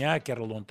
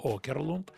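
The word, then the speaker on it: Åkerlund: В нем сменился режиссер, ушел Окерлунд, обратите внимание, не Акерлунд, а Окерлунд, буква А с кружочком наверху, шведская, читается как О.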